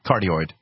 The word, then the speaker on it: cardioid: And so they ran that hoax where they, you know, isolated the microphone that was a corduroy, not corduroy, how do you pronounce it? Cordless microphone? – Cardioid.